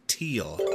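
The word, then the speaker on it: Thiel: I believe that he pronounces it Teal.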